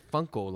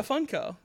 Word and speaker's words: Funko: In my mind, I pronounce it Funko, like a funk band.